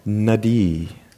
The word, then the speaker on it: nadi: Nadi. – Nadi. – Nadi. – Nadi. – Stress accent could even be more emphasized on the first syllable.